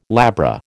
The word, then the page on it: labra: American English